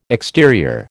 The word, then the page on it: exterior: American English